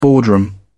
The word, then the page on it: boardroom: British English